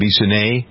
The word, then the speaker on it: Bissonnette: Bissonnette? – Yeah.